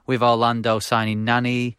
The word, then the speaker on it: Nani: Say it properly. – Nani! – Nani!